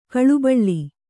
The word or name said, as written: ಗಲಿಬಿಲಿ